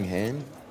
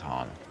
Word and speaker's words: Han: Anything, Han. – That's Han, but that's okay. – In choosing to mispronounce Han's name, Lando is trying to knock Han down a peg.